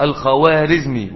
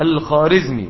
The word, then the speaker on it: الخوارزمي: وهذه الخطة صنعها جنجز خان في قتاله مع جلال الدين الخارزمي، والخارزمي تنطق بالواو الخوارزمي، ولكن هذه الواو تسمى في الفارسية بالواو المعدولة فلا تنطق الخارزمي ولكنها تكتب بالإيه؟